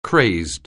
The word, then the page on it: crazed: anglais